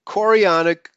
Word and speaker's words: chorionic: Let me try to pronounce that again. Human chorionic gonadotropin, I'll spell chorionic, C-H-O-R-I-O-N-I-C, chorionic gonadotropin, producing a birth control vaccine.